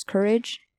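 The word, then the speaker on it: Kurage: And the doctor volunteered Courage, which I don't know if I'm pronouncing that right, but it's K-U-R-A-G-E. So it's Courage. What? You feel like what? – I feel like that was right.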